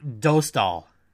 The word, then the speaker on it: Dostal: It's pronounced Dostal. Do. D O U G H, not Da.